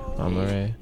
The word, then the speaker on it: Amaarae: A-M-A-A-R-A-E. Amare. – A-M-A-A.